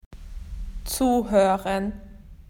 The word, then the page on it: to listen: Australian English